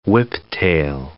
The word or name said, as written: whiptail